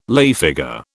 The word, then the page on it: lay figure: British English